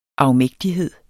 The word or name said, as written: afmægtighed